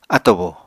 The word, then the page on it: atobo: Apatani